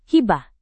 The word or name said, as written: hibah